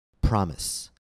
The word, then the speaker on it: promise: Please listen and repeat the following words in which SE after a vowel is pronounced S. House. Mouse. Promise. Use. U-S-E as a noun. Use. Used to. Loose. L-O-O-S-E.